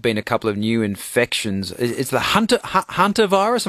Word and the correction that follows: Hanta: Hanta. So Hanta. Discovered in the Hantan River in South Korea, so Hanta virus.